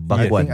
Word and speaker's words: bakwan: I know, but this is pronounced, it's Indonesian, Bakwan.